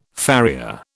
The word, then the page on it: farrier: British English